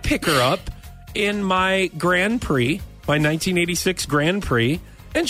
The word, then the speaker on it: Grand Prix: And I'm like, first of all, it's called a Grand Prix. – Oh. – Even though it's spelled P-R-I-X, it's a Grand Prix.